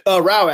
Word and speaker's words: Rausch: Rau, actually. Rhymes with Rau? Cow. It's okay. German.